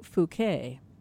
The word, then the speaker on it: Phuket: We've been talking about his trip that he's going to be taking from Dublin all the way to Phuket. That's how you pronounce it.